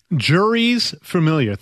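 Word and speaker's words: Jeurys Familia: Juries familiar. Think about a going to a courtroom, juries. More than one jury. Okay. Juries familiar.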